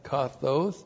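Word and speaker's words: kathōs: The adverb kathos looks like this in the Greek, K-A-T-H, long O, S, kathos.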